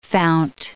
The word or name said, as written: fount